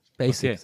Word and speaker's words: Basic: Ach, das ist eher ein schärferes S und nicht ein weiches S. Basic, sagt man, nicht Basic.